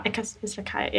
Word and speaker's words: Izakaya: And that's kind of like a Japanese-y Izakaya. Is that how you pronounce it? – Yeah. – Izakaya, yeah.